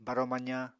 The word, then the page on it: baro-mazañ: Apatani